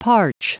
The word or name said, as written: parch